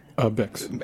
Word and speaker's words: Bix: Bix. – Everyone always silence like prefi. I'm like, the X is pronounced.